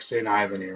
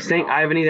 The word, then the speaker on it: St. Ivany: I'm going to go with Jack St. Ivany right now. – St. Ivany, that sounds much better.